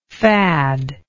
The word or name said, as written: fadlike